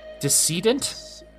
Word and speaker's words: decedent: How do I pronounce that? Deceit? No, there's no E. Oh. There's no N. – There's an N? – Decedent. – Decedent. – I knew I was pronouncing it. Okay. Decedent. Yeah, because it's based off of deceased.